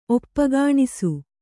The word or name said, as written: ಒಪ್ಪಗಾಣಿಸು